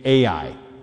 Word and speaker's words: Ai: Now, let's say that city by the name is named Ai.